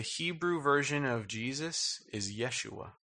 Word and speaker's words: Yeshua: He brought them into the promised land under the leadership of Joshua, which in Hebrew is pronounced Yeshua.